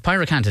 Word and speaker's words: pyracantha: Uh, uh pyracantha. That that that is. Uh, that's the yes. correct pronunciation.